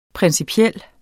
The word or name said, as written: principiel